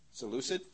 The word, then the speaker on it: Seleucid: Seleucid? Is that how you pronounce it? Excuse me. Seleucid? Sounds good to me.